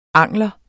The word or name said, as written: angler